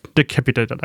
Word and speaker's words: Decapitator: Echo Boy sind natürlich so die Delays ganz schön, aber ich nutze vor allen Dingen auch den wie heißt er nochmal ausgesprochen? – Decapitator? – Decapitator, danke schön.